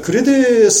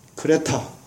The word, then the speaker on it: 크레타: 자, 그레데 섬에서 사역하던 그레데 교회를 책임지고 있던 디도에게, 지중해 그 안에 있는 섬 아닙니까? 그레데, 크레테, 그레데, 크레테. 지금 어떻게 발음합니까? 그 섬을? 크레타. 아, 맞습니다. 크레타. 크레타.